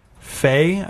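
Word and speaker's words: Fe: Fae, I guess, is how you pronounce this? – Yeah. – F-E? – Yeah.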